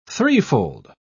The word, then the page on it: threefold: British English